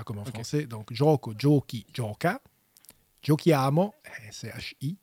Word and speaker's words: giochi: Et pour prononcer giochi, il faut mettre un H. – Oui. – C'est H-I.